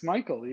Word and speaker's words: Smejkal: Yeah, it's Smichael.